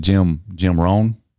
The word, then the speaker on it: Rohn: And it was, I posted a lot of quotes from, is it Jim Rohn? – Yeah. – Is that how you say his name? – Mm-hmm.